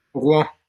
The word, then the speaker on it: Rouen: And I'm going to mispronounce it, but Rouen? – Rouen, yeah. – Rouen, okay. Yeah.